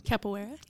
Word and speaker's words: capoeira: capoeira? – Capoeira.